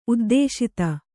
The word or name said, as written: ಉದ್ದೇಶಿತ